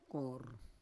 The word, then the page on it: kor: Roon